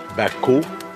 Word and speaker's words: Baku: His name is Baku, and we can see Baku. – The name is phonetically engraved. The crane and the basket stand for the sound Bak, and the chick stands for the sound U.